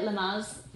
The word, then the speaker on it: Lamaze: Is that how you pronounce it? Lamaze. Those colourful toys. – Yes, they're great.